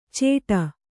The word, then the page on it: ಚೇಟ: cēṭa